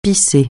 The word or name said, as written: pisser